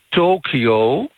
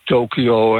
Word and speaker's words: Tokio: In Tokio, daar is het woordje To met het streepje erboven, dus een wat verlengdere syllabe, verwijst naar oosten, het oosten.